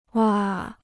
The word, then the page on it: 挖: wā